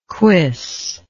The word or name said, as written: cuisse